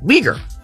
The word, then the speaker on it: Uyghur: Did he say, there's only one way to say it, Uyghur.